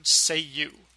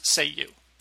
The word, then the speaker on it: Ceiu: Seiyu. It's spelled C-E-I-U, but meant to be pronounced, as I just did, like the English phrase Seiyu.